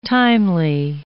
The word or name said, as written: timely